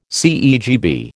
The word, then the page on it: CEGB: American English